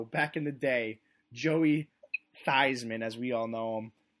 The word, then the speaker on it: Theismann: you should change it to Theismann so it rhymes with Heisman, and you get that super cool Theismann for Heisman type crap.